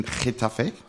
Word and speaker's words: Getafe: The second one is I want to wish good luck to Ali Fallin, who is signed for second division Spanish team Getafe.